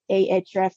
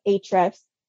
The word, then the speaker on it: Ahrefs: So Ahrefs, which is actually spelled A-H-R-E-F-S. There's a debate between should it be pronounced Ahrefs or Ahrefs, but that's basically code in HTML, which is HTML is what websites are made of essentially.